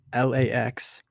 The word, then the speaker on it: LAX: Los Angeles International Airport, commonly referred as LAX, with each of its letters pronounced individually, is the primary international airport serving Los Angeles and its surrounding metropolitan area.